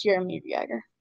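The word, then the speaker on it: Jaromír Jágr: Well, if you read it, it would look like Jaromir Jagr. It's pronounced Jaromir Jagr.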